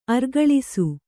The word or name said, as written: ಆರುಕೆಲಸ